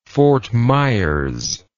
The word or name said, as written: fort myers